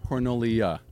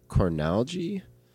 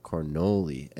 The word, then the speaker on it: Cornoli: Yep, so my grandma said Cornalgie, and then I would roll around town. And people would say, that's not how you say your last name. – Yeah, you're doing it wrong. – I was like, okay, so it's Cornelia or Cornoli. And my dad thinks the most Dutch pronunciation's Cornoli.